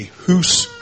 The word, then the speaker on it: hoose: And so it'll all depend on different, and sometimes it'll be the setting of a house group or a life group, or as they call them in Scotland, a hoose group. In England, it's a house group. In Scotland, it's a hoose group. Like, in Scotland, it rhymes with group.